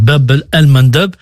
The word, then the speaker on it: Bab el-Mandeb: Je vais commencer par le nom que vous prononcez très bien, Elsa, d'ailleurs, Bab el-Mandeb.